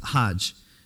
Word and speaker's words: Hajj: That's how they pronounce it, Hajj.